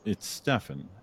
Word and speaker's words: Stefan: I grew up with everyone pronouncing my name wrong. It's Stefan. It's a very simple name, but I would get Steven all the time. There's no V or PH or anything like that, but I would get Steven a lot, or Kevin or things like that.